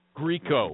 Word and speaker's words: Grieco: Grieco.